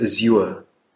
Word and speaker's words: Azure: ich habe immer Azure gesagt, ich habe jetzt gehört, es das heißt Azure.